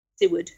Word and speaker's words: Siward: I'd like to move on to the next figure you feature, and that's again, if I apologize if I mispronounce, is it Siward? – Seward. – Siward. And Seward.